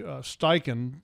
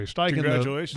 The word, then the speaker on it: Steichen: Well, I mean, first of all, and if you had a chance to watch Steichen, did I say that right? – You did. – Okay, Steichen,